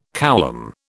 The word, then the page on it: coulomb: British English